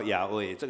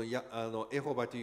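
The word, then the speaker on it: ヤハウェ: 这个还没有到ヤウェイ，这个是开始耶和华，然后后来有人认为这个读音根本是错的，所以改成ヤウェイ。その時にはそのエホバというふうに発音するけども、その後にはこのエホバという発音が間違っていていて、それをヤウェイというふうに読むようにしました。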